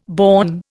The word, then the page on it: born: Indian